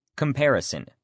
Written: comparison

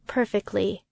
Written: perfectly

